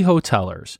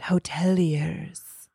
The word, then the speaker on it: hoteliers: Now, the fancy people would pronounce it hoteliers. – Oh, yeah, hoteliers.